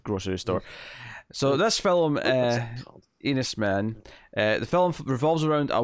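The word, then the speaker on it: Enys Men: So Mark Jenkins, his next film is going to be the Cornichette horror film Ennis Man. Or maybe Ennis Man. Maybe it'll be Ennis Man. But I'll say, sorry, I butchered this title. Enos Men, not Man.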